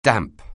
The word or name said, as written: damp